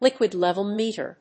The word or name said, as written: liquid level meter